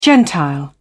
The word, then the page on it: gentile: British English